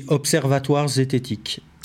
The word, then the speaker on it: Observatoire Zététique: How do you pronounce the name of the organization properly? – It's the Observatoire Zététique.